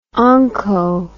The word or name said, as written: onco